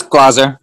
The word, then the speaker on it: Glaser: Scott, did I pronounce that right? Scott Glaser. – That's Glaser. Yeah.